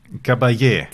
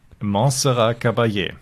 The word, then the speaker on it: Caballé: Montserrat Cabal. Nein. – Caballé. – Caballé. Bitte nochmal als Ganzes zum Reinschneiden. – Montserrat Caballé.